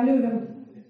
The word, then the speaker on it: Левин: или как в Ясной Поляне он правильно говорит, Левин, да? Скажите мне. Настоящие яснополянские люди говорят Лев Николаевич и, соответственно, Левин.